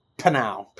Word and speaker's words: PNAU: PNAU, PNAU, which we speculated on how to pronounce that, is indeed like panow.